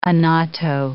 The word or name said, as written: annatto